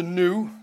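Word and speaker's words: gnu: That's a new, that's how you pronounce it. It's a silent G, new.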